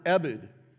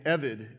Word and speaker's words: ebed: The word that underlies this, and it's the reason why I had Hank read additional passages, is the Hebrew word ebed, or if it's a soft B, an evid is how you might want, either way you'll hear it pronounced.